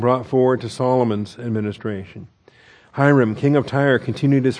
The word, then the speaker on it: Hiram: So, we get introduced here to Hiram, or Hiram. I call him Hiram. I guess the Hebrew pronunciation.